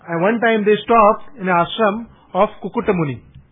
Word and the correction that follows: Kakutmuni: Do you want to get the name right? Yeah, Kakutmuni, K-K-K-U-T.